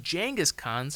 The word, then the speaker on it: Genghis Khan: And if you're interested in learning more about Genghis Khan, which is actually pronounced Genghis Khan, surprisingly, I point you no further than Dan Collins' Hardcore History.